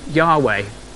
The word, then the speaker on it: Yahweh: They added some vowels so that you can say it, so the A and the E get added, so it becomes Yahweh. So the name Yahweh.